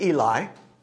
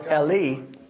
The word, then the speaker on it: Eli: We pronounce it Eli. It's probably best pronounced Eli, my God.